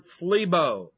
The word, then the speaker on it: thlibo: The word troubled in verse 8 is the word thlibo, T-H-L-I-B, long O.